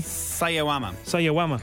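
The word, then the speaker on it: Sawayama: Marina Sayayama, is that, am I pronouncing that correctly? – Sayawama.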